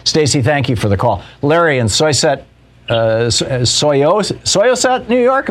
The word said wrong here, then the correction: Syosset: Am I saying that right, Larry? – Close. Syosset. – Syosset.